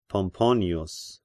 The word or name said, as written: Pomponius